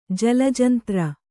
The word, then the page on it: ಜಲಜಂತ್ರ: Kannada